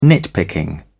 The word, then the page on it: nitpicking: British English